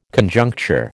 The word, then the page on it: Conjuncture: American English